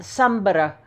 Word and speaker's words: Sumburgh: My family lived on the mainland, on the southern tip at Sumburgh, which, you know, that's how they say it there. It's like Edinburgh.